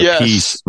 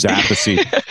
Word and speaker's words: Appice: You know, it's a piece versus Appice.